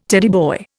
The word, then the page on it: Teddy boy: Indian